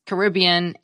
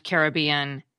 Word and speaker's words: Caribbean: It turns out most dictionaries list both pronunciations, Caribbean and Caribbean, and say both are widely accepted.